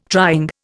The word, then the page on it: trying: Indian